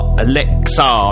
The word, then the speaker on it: ELXAR: Right, the final new tune this evening is from Alexa, E-L-X-A-R, Alexa, that's how I'm pronouncing it.